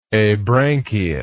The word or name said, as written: abranchiate